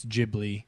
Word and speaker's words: Ghibli: It says it's the hard G. It's Ghibli. – Yeah. – Not the soft Italian G.